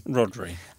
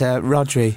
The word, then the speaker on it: Rhodri: We was emailing each other all week, and he spelt his name... R-H-O-D-R-I. – Hmm. – How would you pronounce that name? – Rodri.